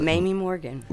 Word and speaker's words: Mamie Morgan: Mamie Morgan.